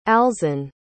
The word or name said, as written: alzan